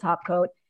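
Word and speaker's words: Taupecoat: We spell topcoat like the word taupe, the color taupe. So it's spelled taupe coat, but we pronounce it topcoat.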